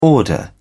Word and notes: order: British English

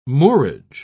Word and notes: moorage: British English

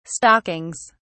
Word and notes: stockings: British English

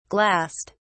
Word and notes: glassed: British English

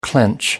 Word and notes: clench: American English

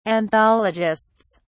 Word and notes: anthologists: British English

